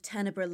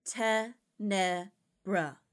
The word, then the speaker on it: Tenebra: You sullied the good name of Teneber Laboratories. First of all, by pronouncing it incorrectly. Say it with me, Gregory. Te Nebra.